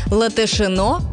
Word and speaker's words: Латышино: Латышино произносится именно так, а вовсе не с ударением на второй слог, как любят многие.